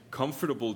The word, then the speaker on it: comfortable: 그러면은 너는 comfortable 제대로 좀 발음을 해봐요. 미국에서 우리 comfortable 이렇게 다 풀어서 하는데. comfortable. 많은 영국 친구들은 내 친구 벤 있잖아요. comfortable. 그 안에 있는 그 뭐지? 음절 그냥 빼버리는 거예요.